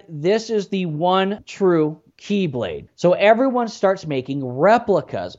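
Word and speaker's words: Keyblade (χ-blade): It's actually spelled X-blade, but yeah, it's pronounced Keyblade.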